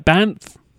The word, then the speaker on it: Banff: BANFF? – Yep, that's right, yes.